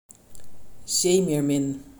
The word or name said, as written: zeemeermin